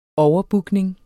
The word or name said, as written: overbookning